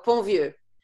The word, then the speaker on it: Pontvieux: Delphine, how do I say your last name? – Oh, Ponvieux. – Ponvieux. Yeah.